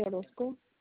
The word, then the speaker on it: Orozco: Natalie Orozco. – Spell it for me. – N-A-T-A-L-I-E. Last name O-R-O-Z.